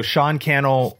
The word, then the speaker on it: Sean Cannell: So Sean Cannell rhymes with YouTube channel on uh, on Instagram and all the social media platforms. That's S E A N for Sean, and then my last name Cannell, C A N N E L L.